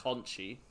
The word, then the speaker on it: Conti: That is Tay Conti. – Oh, sorry, forgive me. – I believe it's pronounced Conchi, but anyway, it's pronounced however the fuck I want.